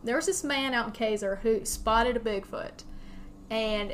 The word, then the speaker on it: Casar: There's this place called Caesar. Now, it's spelled C-A-S-A-R. Apparently, it's supposed to be called Caesar. They spelled it wrong, so it's Caesar.